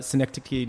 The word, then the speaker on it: Synecdoche: Adaptation, Synecdoche, New York.